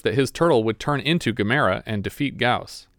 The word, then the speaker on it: Gamera: Gamera, Gamera, Gamera, Gamera. – No, it's more like Camera versus Gamera. So you're putting the emphasis on the second syllable instead of the first syllable.